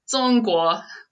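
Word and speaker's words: Zongguo (中国): Zongguo.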